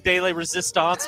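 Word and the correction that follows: pièce de résistance: Probably said that wrong. Piece de resistance.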